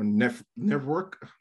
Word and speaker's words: NetVRK: There is NetVRK or Network. I'm not pronouncing it well, but it's NetVRK. Yeah, the certain way you pronounce it, that sounds like network, but that's it.